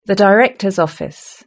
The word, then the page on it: the director's office: British English